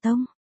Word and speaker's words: Tông: Bản cũ dịch là Tôn Duy Hiệp nhưng đúng ra phải đọc là Tông.